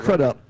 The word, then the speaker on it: Crudup: No, I said Crudup. – Oh, okay. – I want to make sure you understood I pronounced it right.